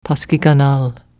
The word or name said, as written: paskiganal